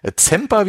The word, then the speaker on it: Semper: Nochmal vielen lieben herzlichen Dank an den Christoph äh, Zemper, wie es ja original ausgesprochen wird. Wir Deutschen sagen ja immer Camper. Ja, genau.